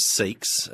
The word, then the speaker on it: Seeckts: So the last name is Seeks. It is spelled S-E-E-C-K-T-S, and if you just get rid of the C and the T. And everything's plain sailing.